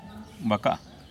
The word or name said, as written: bakà’a